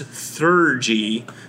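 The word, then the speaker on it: Theurgy: So you have a new otherworldly patron, the Seeker, and a new arcane tradition, which is pronounced Thurgy.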